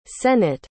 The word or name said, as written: Sennett